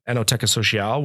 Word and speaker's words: Enoteca Sociale: Enoteca Sociale, which you pronounced perfectly earlier.